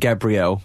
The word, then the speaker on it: Gabriel: I think it is pronounced Gabriel, like the singer.